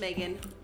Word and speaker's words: Megan: My name is Megan. – Megan. How do you spell it? – Easiest way you can. M-E-G-A-N.